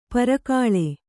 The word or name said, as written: ಪರಕಾಲ